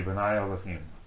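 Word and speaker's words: B'nai Elohim (בני האלהים): So it's the B'nai, like to pronounce it, it would be B'nai Elohim, sons of God, okay?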